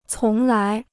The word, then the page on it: 从来: cóng lái